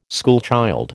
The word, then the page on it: schoolchild: American English